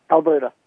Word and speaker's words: Alberta: Alberta.